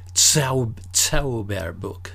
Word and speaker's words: Zauberbuch: And I didn't understand until I realized that the Z is pronounced differently. It's Zauberbuch. And once I made them understand that I wanted Zauberbuch, not Zauberbuch, then they took me to the magic books.